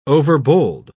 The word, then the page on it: overbold: British English